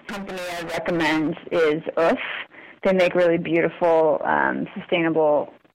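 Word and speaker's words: Oeuf: It's spelled, the French word. It's spelled like eggs? – Sorry. Yeah. O-E-U-F, I believe.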